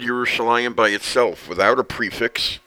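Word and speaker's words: Yerushalayim (ירושלים): In other words, Yud, Resh, Vav, Yeru, Shah, Shin, Lamed, Mem, Sofit, Yerushalayim. But it's pronounced Yerushalayim as if there would be a Yud between the Lamed and the Mem.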